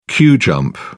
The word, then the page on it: queue jump: British English